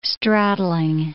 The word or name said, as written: straddling